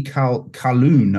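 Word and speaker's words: Colquhoun: So it was Eddie Calhoun, I think is the pronunciation.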